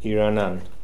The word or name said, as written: Iranan